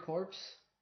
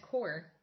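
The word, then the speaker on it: corps: Do you remember the corpse? That were like a cheap version of G.I. Joe? You get them for £1. – But I think that's pronounced core. – Oh, sorry, yeah. – Like corporal? – But we call them the corpse.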